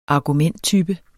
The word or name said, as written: argumenttype